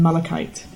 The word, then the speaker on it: malachite: Six steps from nearest to farthest are made of onyx, pink marble, lapis, black marble, yellow serpentine, and the colour I cannot pronounce, but it's malachite. Or malachite. – Malachite. – There you go.